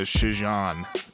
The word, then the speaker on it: Chijon: And this is spelled C-H-I-J-O-N. So I'm going to be pronouncing it as Shijon.